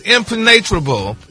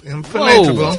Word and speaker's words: impenetrable: Impenetrable. I-M-P-E-N-E-T-R-A-L-E. – Now, your emphasis is on the wrong syllables in every way.